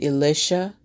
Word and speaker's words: Elisha: Elisha, or as some people may pronounce it, Elisha, I'm going to pronounce it Elisha, S-H-A-E-L-I-S-H-A.